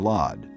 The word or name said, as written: Lod